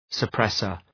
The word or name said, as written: suppressor